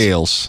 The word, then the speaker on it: Ailes: Ailes.